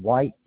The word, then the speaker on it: Whyte: Howard White. White is spelled W-H-Y-T-E.